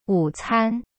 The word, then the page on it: 午餐: wu-can